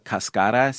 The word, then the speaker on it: cascara: Well, a lot of places that sell it here call it cascara, C-A-S-C-A-R-A. That's the Spanish cascara. – Word for it. – Yeah. Cascara.